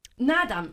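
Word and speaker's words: Naadam: J'ai entendu parler, alors je ne sais pas trop comment on prononce, le nadam. – Nadam, oui. – Nadam. – Ah oui, on dit, comme en fait il y a deux A, on dit nadam.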